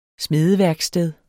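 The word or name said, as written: SMEDEVÆRKSTED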